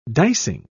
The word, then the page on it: dicing: British English